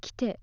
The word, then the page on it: kite: Japanese